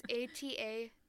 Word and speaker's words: Atascadero: And it's spelled crazy, too. It's like sort of not even spelled the way that it's pronounced. – Yeah, I couldn't remember for sure. It's Atascadero.